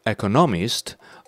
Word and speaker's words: economist: He says economist, economy, economist. So second syllable should be stressed, not the third.